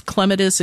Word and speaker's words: clematis: It depends on the clematis, and you can pronounce it any way you'd like. It's clematis. It kind of depends on where you're from as to how you pronounce it, but both are correct.